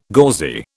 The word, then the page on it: gauzy: British English